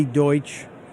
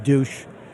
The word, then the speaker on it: Deutsch: I know some of you are going to call in and correct me and say that's pronounced douche. It's not. That may be what he is, but it is pronounced Deutsch.